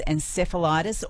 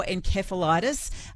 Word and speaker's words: encephalitis: Japanese encephalitis or encephalitis. You'll hear both pronunciations, no doubt, when you hear about this.